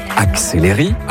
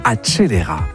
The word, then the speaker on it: accelera: Lio dit « acceleri », alors que le mot italien doit plutôt sonner « accelera ».